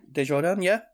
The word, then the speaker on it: Desjardins: I think that's how you pronounce her name. – Desjardins. – That sounds better.